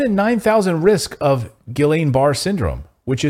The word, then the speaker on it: Guillain-Barré: Am I saying that right? Guillain? I think I'm saying that right? – I think it's Julian, isn't it? – Julian? Yeah. Guillain-Barré? – No, Julian, because it's... You were saying it right the first time. I've heard it pronounced Guillain-Barré, like you were saying. – Guillain-Barré? Okay.